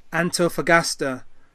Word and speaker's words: Antofagasta: Antofagasta, if I've pronounced that correctly, 17%.